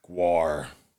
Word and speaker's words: Gwar: You had to go look for pieces of concert tickets so you could go and see Beavis and Butthead's favorite band, Gwar. – Gwar. – How do you spell that? G-W-A-R.